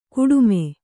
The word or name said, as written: ಕಾಟಿಮೆ